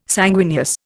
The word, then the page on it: sanguineous: Indian